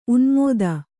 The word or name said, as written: ಉನ್ಮೋದ